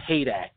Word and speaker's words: Heydecke: If just in case if you don't know how to say my last name, a lot of people don't. They mispronounce it. It's Heydecke. A lot of people say Heydecke and all that stuff, so Ian Heydecke.